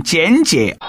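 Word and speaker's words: 尷尬: 在台湾，他们的所谓的教育部门认定，尴尬也可以读成简介。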